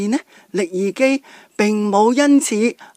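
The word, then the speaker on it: 食其: 上次都有提过呢，力而基佢个名而基啊，若果写出嚟呢就等于食同埋奇。不过呢要读呢就读成而基嘅。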